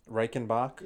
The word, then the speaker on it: Reichenbach: Reichenbach. Did I say it right? – Reichenbach? – Yeah, I said it right. You said it right.